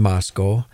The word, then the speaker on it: Moscow: Notice that he said Moscow, not Moscow. It is Moscow. And while I'm on that kick, it is Boise, Idaho, not Boise, Boise. So remember that next time and do a good Idahoan a favor by pronouncing those names right, Moscow and Boise.